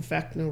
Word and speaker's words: Vecna: no, you got L and Vecna, right? – I said Vecna, but anyway.